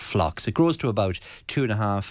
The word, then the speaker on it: Phlox: So that's Phlox. So the P is silent. – Okay. Yes, like pharmacy.